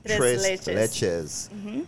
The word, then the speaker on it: tres leches: Tres leches. Tres, am I pronouncing it right? – Tres leches.